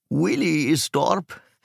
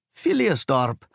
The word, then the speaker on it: Philiersdorp: Es war ein bisschen lustig, denn sie hat den Namen ganz falsch ausgesprochen. Williesdorp, mit ihrem amerikanischen Akzent, so dass ich sie erst gar nicht verstanden habe. Ich musste sie bitten, es mir aufzuschreiben, und dann sagte ich, oh, Philiersdorp.